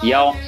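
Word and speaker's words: Яо (幺): Ну, потому что по-китайски получается так, что цифра 1 в данном случае читается Яо, хотя многие знают, что это И, вот, да, у нас ER sign 1, 2, 3, но в больших таких вот числах это читается часто как Яо, и значит, и звучит похоже как вот то, что должно случиться, да, то есть ты разбогатеешь, Яо-Па-Па, это значит...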